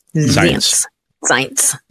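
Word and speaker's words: Zients: Ron Klein, que es el actual jefe de gabinete, estaría siendo reemplazado por, por uh, oh, uh, Jeff Science o Science, ¿cómo se pronuncia? – Science. – ¿no? Así con Z.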